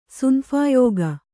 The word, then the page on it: ಸುನಫಾಯೋಗ: sunaphāyōga